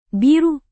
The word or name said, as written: biru